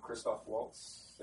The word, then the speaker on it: Christoph Waltz: Not to say the writing was horrible, like, I feel like, I'm going to say it wrong, Christoph Waltz, did I say it right? – Oh, yeah.